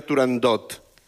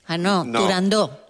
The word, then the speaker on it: Turandot: No se debe pronunciar Turandot. – Turandó. – La ópera se titula Turandó.